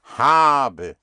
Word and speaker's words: habe: D-E-N-N, I don't have it. – Denn ich habe es nicht. – Denn ich ha, put the stress on the A, habe.